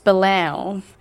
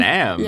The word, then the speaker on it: Belém: Actually, it's, I think, pronounced Belém. You don't really pronounce the M. – Belém.